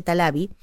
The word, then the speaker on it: Taliavi: el apellido se escribe Taliavi, pero se dice Talavi, eh, la convoca eh, durante 1907 para que realice una serie de presentaciones junto con la compañía.